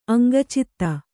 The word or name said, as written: ಅಂಗಚಿತ್ತ